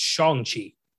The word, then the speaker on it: Shang-Chi: Well, first off, I did hear it pronounced. It's Shang-Chi.